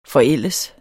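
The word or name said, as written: FORÆLDEDES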